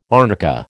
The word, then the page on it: arnica: American English